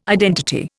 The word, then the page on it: identity: Indian